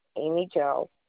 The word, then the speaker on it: Amy Jo: A is in apple, M is in Mary, Y is in yak, J is in Jack, O is in octopus, Amy Jo,